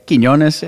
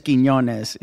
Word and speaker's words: Quinones: It's like one ñ. I think you got a ñ going, but it's just Quinones.